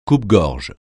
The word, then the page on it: coupe-gorge: French